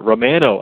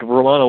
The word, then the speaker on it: Romano: Um, and our friend from up north will love to hear that Jordan Romano out of Markham, Ontario, or uh, Romano, I guess, as it's pronounced.